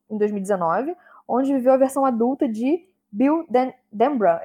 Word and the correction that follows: Denbrough: Eu falei certo? – Denbrough. – Ah, tá. Perdão, que eu sou britânica, aquelas. na ver a versão adulta de Bill Denbrough.